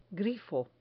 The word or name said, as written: grifo